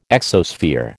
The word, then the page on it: exosphere: American English